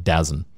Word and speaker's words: DAZN: I was calling it DAZN. – That's how I pronounce it. – But again, spelled D-A-Z-N is DAZN.